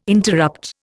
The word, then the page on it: interrupt: Indian